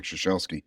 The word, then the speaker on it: Ciesielski: I don't even remember what she said, but you have to imagine, with a name like Ciesielski, I've heard thousands of iterations.